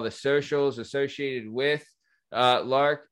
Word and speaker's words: Learke: So the the name of the so the the shop is called Lark, right? Mm-hmm. – And yes. – it's spelled L E A R K E.